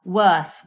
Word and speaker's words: Wearth: There is a company called Wearth, which is like We Earth, in all in one word. So it's spelt W-E-A-R-T-H.